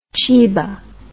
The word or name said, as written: cheeba